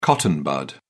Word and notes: cotton bud: British English

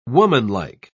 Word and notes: womanlike: British English

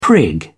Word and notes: prig: British English

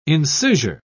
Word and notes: incisure: British English

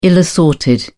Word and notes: ill assorted: British English

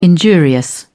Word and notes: injurious: British English

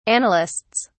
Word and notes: annalists: British English

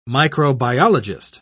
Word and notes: microbiologist: British English

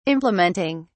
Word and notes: implementing: British English

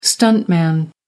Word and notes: stuntman: American English

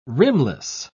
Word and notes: rimless: British English

